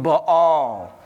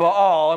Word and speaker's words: Baal: And this went on, we find out, because the Israelites were worshiping Baal. And that is how you say that, in case you're wondering. But anyway, they were worshiping Baal, and we said that that is the spirit of control.